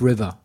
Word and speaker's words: Rivia: Das heißt übrigens doch Geralt of River. – Of River, okay. – Ja, nicht Rivia.